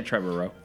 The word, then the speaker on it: Trevorrow: So, Colin Trevorrow, am I saying that right? – Trevorrow. I would say Trevorrow.